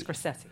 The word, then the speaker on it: Scorsese: Because, and this is also, this Scorsese. – Connects up to one of the Scorsese. – Scorsese.